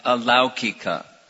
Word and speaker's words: alaukika: The slide where there is a difference between laukik and alaukik, in the Vedas, there is an alaukik language and... – That's just the pronunciation is laukika and alaukika.